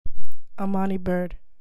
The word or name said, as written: Amoni Byrd